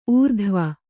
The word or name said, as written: ಊರ್ಧ್ವ